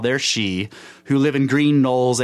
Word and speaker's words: sidhe: We are now prepared to hear about the Dwan Maith, the good people, as the Irish call their she race. Now, she is interesting. It's spelled S-I-D-H-E, but it's pronounced she, okay? So when you hear of places like Glen She in Ireland, it's spelled S-H-E-E now, but Glen She means the Glen of the Fae, okay?